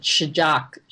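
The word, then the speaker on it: Sjaak's: All I know is that for Easter, I found the, I don't know how to pronounce this, S-J-A-A-K. How do you pronounce it? Shajak, Jack chocolate.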